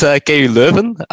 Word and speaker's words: KU Leuven: Yeah, it's KU Leuven from Belgium.